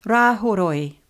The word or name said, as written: Rahoroi